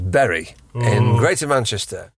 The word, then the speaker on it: Bury: At time of recording, it was believed that the correct pronunciation of Berry was indeed Berry. However, upon further research, it does appear that both Berry and Bury are seen as the correct way of pronouncing it, depending on which side of the town you are from.